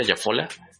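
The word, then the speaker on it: Jafolla: Richard Jafola, se pronuncia Jafola,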